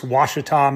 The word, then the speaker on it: Ouachita: Yeah, I think it's got to be like the sixth or seventh oldest 100-miler, but it's in the it's pronounced Ouachita Mountains, which are kind of right in the center of the state, just a little bit west of Little Rock.